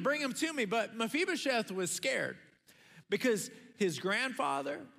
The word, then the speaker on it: Mephibosheth: And you pronounce it Mephibosheth.